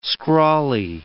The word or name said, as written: scrawly